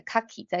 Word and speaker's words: 牡蛎: 那最好是有一个，就是、嗯、呃，老师可以纠正你的发音，或者是说你利用一个有音轨的教材，那持续的跟着他念，而不是说啊，我要去记这个筷子的发音哈西，那它的重音是在哈上面，然后那个。牡蛎的重音是在卡基，在卡上面，不是这样子记哦，就是只要你重复的一直利用音轨来聆听的话，那你就自然而然的容易记起来这个发音这样子。